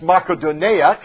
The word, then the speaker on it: Makedonia: And the dream is a man in Macedonia saying, and it's actually pronounced Makedonia. Can you say that with me? Makedonia. It's Macedonia to us most of the time.